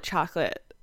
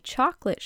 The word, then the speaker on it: Chocolat: I would call it chocolate all the time. And I was like, I don't have a French accent. Why am I pronouncing it the other way?